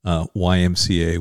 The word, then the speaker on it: YMCA: With all the things at the Y, but he always said YMCA with the emphasis on the C, mm-hmm.